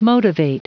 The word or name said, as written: motivate